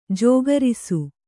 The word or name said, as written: ಜೋಗರಿಸು